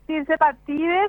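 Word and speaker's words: tirzepatide: Es tirsepatide, se puede decir en inglés, se escribe tirsepatide y en español se dice tirsepatida, pero es la misma droga.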